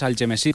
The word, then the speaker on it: Algemesí: Pues bueno, nosotros al pueblo que fuimos es Algemesí, como se pronuncia en valenciano.